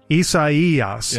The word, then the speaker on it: Isaias: Hurricane Isaias. – There we go. – Isaias is the way you pronounce it.